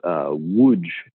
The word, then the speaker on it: Łódź: So I did a self-guided residency in a place called Łódź, Poland. So it's L-O-D-Z, but none of those letters pronounced the way that it's spelled.